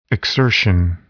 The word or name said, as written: exertion